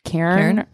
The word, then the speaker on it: cairn: It's C-A-I-R-N. I don't know how you pronounce it. Karn or Cairn? – Cairn. That sounds right to me.